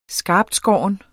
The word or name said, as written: skarptskåren